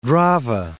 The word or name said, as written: Drave